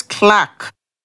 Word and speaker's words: clerk: Clerk is pronounced as clack.